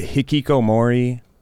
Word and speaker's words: hikikomori: it's like there's the hikikomori, if I'm saying that correctly. – Yeah, that's right.